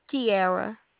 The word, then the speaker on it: Kieara: Kiara. – How do you spell that? – K-I-E-A-R-A.